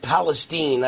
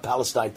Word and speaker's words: Palestine: I want to tell you about my thoughts on East Palestine. I think that's the way it's supposed to be pronounced, not Palestine.